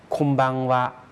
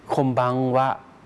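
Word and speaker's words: ค มบัง วะ (こんばんは): เนื่องจากว่ามีตัวบะตามหลังมาตัวอึก็เลยออกเสียงคล้ายๆเป็นตัวเอ็มตัวมอม้านะครับคมบังวะคมบังวะ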